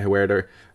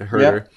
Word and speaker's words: Huerter: I'm also going to be looking at Huerter or Herter, however you'd like to pronounce Herder. – it, Herter.